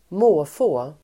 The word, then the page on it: måfå: [²m'å:få:]